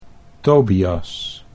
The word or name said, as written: Tobias